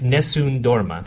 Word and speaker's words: Nessun Dorma: Now, pronounce that for me again. – Well, I'm not sure I'm right. But you're right. – I think it's Nessun Dorma. – That's what it is.